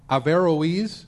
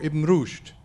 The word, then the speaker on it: Averroes: and Averroes, which is another very bad pronunciation for Ibn Rushd.